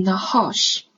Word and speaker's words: roche: 我们一起来看一下，une roche，une roche。